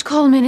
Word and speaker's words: Coldman: I thought the D was silent. – It is pronounced Coleman, isn't it? We've had some confusion. – Oh, yes, Coleman. The D is silent in America.